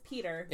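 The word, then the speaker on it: Piotr: How do you say his name? It is Peter. – Yeah. – But it's P-I-O-T-R. – Yeah. – Russian spelling.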